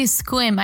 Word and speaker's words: Sequim: Squim is like S-E-Q-U. It's a very, Like Sequim. – Yeah. Don't say it like it's spelled. Say it like squid. But with an M.